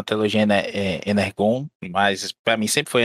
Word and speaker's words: Energon: Bom, o, o Energon, né, apesar de escrever com G, é pronunciado como se fosse um J, essa é uma, uma maluquice franquícia aí desde os princípios da, da franquia.